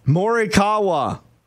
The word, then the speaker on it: Morikawa: And on Saturday, when Colin, how do you say his name? – Morikawa, coach.